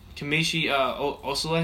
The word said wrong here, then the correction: Kelechi Osemele: is that how you say his name? – Kelechi Osemele. – Yeah, Kelechi Osemele. Yeah, I botched that one.